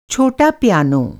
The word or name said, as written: प्यानो बजाना